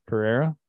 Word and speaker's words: Pereira: I'm between Mito Pereira, or however Pereira. You pronounce that. Pereira. There you go.